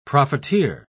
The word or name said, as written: profiteer